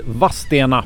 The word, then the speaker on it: Vadstena: Vilken dag vi har haft i Vadstena, för det är så man uttalar stadens namn.